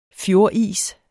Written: fjordis

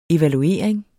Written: evaluering

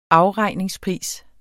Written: afregningspris

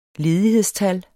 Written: ledighedstal

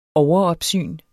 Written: overopsyn

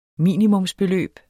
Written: minimumsbeløb

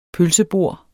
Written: pølsebord

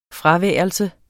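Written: FRAVÆRELSE

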